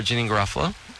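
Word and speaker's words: Janeane Garofalo: And so for Janine Garofalo.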